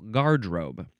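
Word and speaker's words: garderobe: You have to emphasize the first syllable. Gardrobe.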